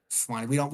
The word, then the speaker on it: Sewanee: We call it Sewanee. Sewanee. We don't pronounce the E. Sewanee. – Sewanee. Look, Mom, I'm native.